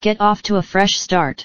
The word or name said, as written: get off to a fresh start